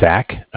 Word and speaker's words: Zach: Yeah, my name is Zach, spelled Z-A-C-H.